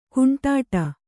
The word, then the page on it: ಕುಂಟಾಟ: Kannada